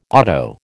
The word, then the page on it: otto: American English